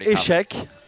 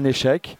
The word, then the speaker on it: échec: So you've got échec, an échec, like the chess game, échec, or faillite. More than fracaso, yeah.